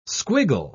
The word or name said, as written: squiggle